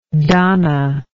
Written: Dahna

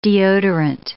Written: deodorant

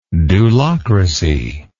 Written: dulocracy

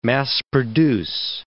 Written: mass-produce

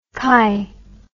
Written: kai

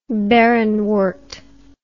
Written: barrenwort

